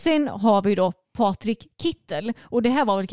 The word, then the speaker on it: Kittel: Jag har hört både shit Kittel och Kittel, men det är Kittel som mm.